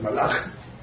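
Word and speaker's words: Malach (מלאך): Malach. – Very good, Malach. Okay, how much is Malach? Mem is what? 40. Lamed is 30. Aleph is 1. That's 71. Chaf is 20. 91. Malach is also 91.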